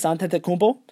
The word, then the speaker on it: Antetokounmpo: And the Dallas Mavericks have Dennis Smith Jr., Luka Doncic, DeAndre Jordan, Harrison Barnes, and Kostas Antetokounmpo. And that's how it's pronounced.